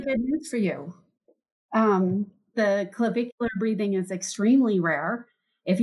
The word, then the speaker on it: clavicular: Is it clavicular or clavicle breathing? – Clavicular. – Oh, I did say it right.